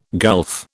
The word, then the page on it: gulf: British English